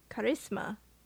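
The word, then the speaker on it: Karisma: It is Krishna. – Yes. – Charisma. – Charisma. – I've been saying her name wrong the whole time because I think it was spelled differently than it is here on the IMDb's.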